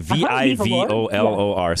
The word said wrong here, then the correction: Vivalore: Say it again, Susan. – Yes, Vivalore.